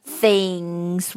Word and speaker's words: things: 嗯，我们往下面说，第二句话，The things we used to say，这里的单词 the 和 things 这两个单词都是要咬舌头的，the the the things we，这里有个连读，sweet。